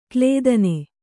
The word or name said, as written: ಕ್ಲೇದನೆ